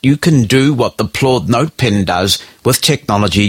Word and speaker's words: Plaud: This is the promise of a little device called the Plaud Note Pen, and Plaud is spelled P-L-A-U-D.